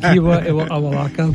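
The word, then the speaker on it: Iwa-Alaka: Iwa Alaka, but the hyphen is silent. Because there's a hyphen in there, but we never pronounce it.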